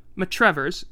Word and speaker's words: Matravers: And in 1797, he moved from Yetminster and he was at another farm in Worth Matravers, or Matrevers, or how do you pronounce this? Matrevers. Matrevers, yeah. Okay.